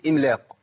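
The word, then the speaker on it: إملاق: انا عندي الميم ساكنه في كلمه املاق وقبلها همزه ايه؟ همزه مكسوره، اقول ايه؟ املاق، املاق، ام ام، ما اقولش املا، ما ينفعش، هي هي كده املاق.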